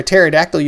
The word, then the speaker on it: pterodactyl: uh, why can't you hear a pterodactyl use the bathroom? The P is silent.